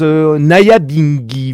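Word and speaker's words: Nyabinghi: puisque ça se prononce Naya Bingi.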